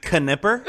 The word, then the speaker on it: Knipper: That was until 1901 when an actress named Olga Nipper, Nipper? Knipper. – It's pronounced Knipper.